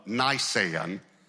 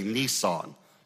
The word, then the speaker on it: Nisan: The day is the 14th of Nisan, is how you pronounce it. Most people incorrectly pronounce it. It's spelled N-I-S-A-N. Most people say Nisan. That's a car you drive, okay? That's not a month in Israel. So it's Nisan, and it's the 14th day.